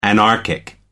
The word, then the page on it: anarchic: American English